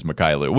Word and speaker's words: Mykhailiuk: He mispronounced it, but it is Mykhailiuk.